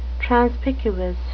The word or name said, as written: transpicuous